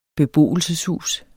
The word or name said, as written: beboelseshus